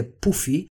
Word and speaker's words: Puffy: όπου Puffy γράφεται με γιου και στα ουγγρικά προφέρεται Πούφι και σημαίνει χοντρούλης.